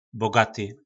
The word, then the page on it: Bogaty: Po našimu